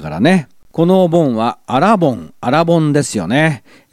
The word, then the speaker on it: 新盆: 信州では新しいお盆と書いてアラボンですけどね。私が生まれ育った千葉県では同じ字を書いて新盆って呼んでます。